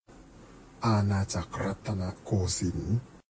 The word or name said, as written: Anachak Rattanakosin